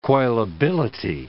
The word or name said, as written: coilability